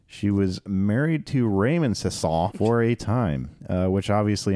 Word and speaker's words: Sasson: Sasson.